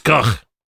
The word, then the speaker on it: Gogh: Because it's Gough. – Because it's Gough. They pronounce it like, you know, like G-O-F-F.